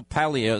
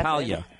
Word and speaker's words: Paglia: Camille Paglia is with us. And even though it's spelled P-A-G, it's Paglia. Did I say it right? I think I did. – Paglia.